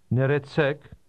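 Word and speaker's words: Neretsek (Ներեցեք): Did you hear how the speaker stressed the last part of those two words? – Neretsek.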